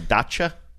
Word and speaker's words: dacha: In fact, I think I was, I got into the spirit, and I think I was more cutting towards the dacha. Dacha? Mm-hmm. I need to start saying it right. Dacha? – Dacha. Yeah, dacha.